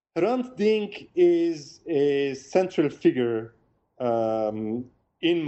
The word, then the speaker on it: Hrant Dink: And you begin the book by talking about someone named, if I'm pronouncing the name correct, Hrant Dink. – Yes.